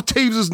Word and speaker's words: Toews: Oh, by the way, those of you who are incorrect and not Blackhawks fans, his name is spelled T-O-E-W-S, but for some reason he pronounces it Taves.